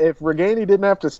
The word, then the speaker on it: Ragaini: um, I wanted to ask you guys about Nico Ragaini. Is that how you say it? Right. – Ragaini. – Ragaini. Yeah.